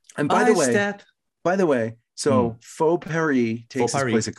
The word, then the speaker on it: faux: No, I'm going to pronounce it the French way. – Ooh. – Faux Paris.